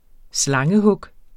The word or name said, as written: slangehug